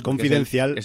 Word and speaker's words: Confidencial: Y además me doy cuenta que como el título original es Black Bag, yo he pronunciado luego Confidential y no, es que es Confidencial. – Es Confidencial.